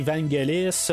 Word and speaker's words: Vangelis: Ben, on n'arrête pas de dire Vangelis, mais en même temps, je regarde sur Google on dit Vangelis. Euh, fait en tout cas, euh, j'imagine que Google a raison, hein, quelque part, euh, ce qu'on a plus élaboré d'essayer de savoir comment on prononce son nom.